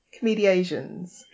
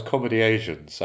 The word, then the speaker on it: Comediasians: Because I thought it was a really bold choice to call yourselves Comedy Asians. I'm like, well, that's a strong move, that is. So I'm relieved that's not how you pronounce the name.